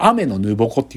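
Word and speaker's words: 天沼矛: で、その園長先生が言うんだけど、雨のぬぼこっていうね、これ実は大災害の引き、引き金なんだけど、小惑星が落ちてくるってことを AI が予言してて、その、それを雨のぬぼこって読むんだけど、その雨のぬぼこっつうのは、えっ、ー、と、イザナギとイザナミが天から下界に海をかき回した矛のことなんだって。そう、すごいよね。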